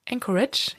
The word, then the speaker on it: Anchorage: Also obwohl sein Geschäft gut läuft, also er ist ja ein erfolgreicher Bäcker in Anchorage, ich sage es richtig. Ich habe ein bisschen böse Post bekommen, dass ich diese Stadt falsch ausspreche. – Böse Post.